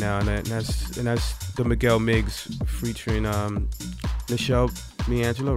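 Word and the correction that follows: Meshell Ndegeocello: I probably butchered her name. – Oh, so. – Michelle Ndege Ocello. – And there it is.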